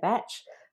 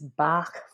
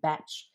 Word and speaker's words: Bach: Yes, I did say batch. Germanized way of saying it is Bach flower remedies, but the pronunciation of his name is actually batch or Bach in his part of the world.